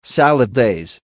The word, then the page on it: salad days: American English